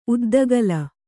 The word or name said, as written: ಉದ್ದಗಲ